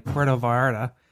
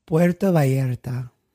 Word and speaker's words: Puerto Vallarta: I don't want to be like Puerto Vallarta. – All right, how is it pronounced? – Puerto Vallarta. – There we go.